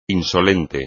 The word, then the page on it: insolente: Spanish